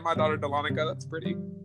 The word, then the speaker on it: Dahlonega: and he pronounced it the Dahlonega. That is not how it's pronounced. It is pronounced Dahlonega.